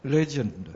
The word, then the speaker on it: legend: And the accent is on the first syllable, legend.